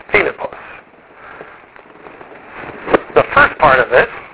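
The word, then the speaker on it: Filippos (Φίλιππος): So how do you pronounce it? Well, look where the accent mark is. Filippos.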